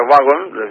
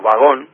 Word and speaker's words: vagón: Por ejemplo, en vez de wagon, decimos vagón, decimos comité, decimos confort, no comfort, ¿vale? – Sí, sí, es verdad.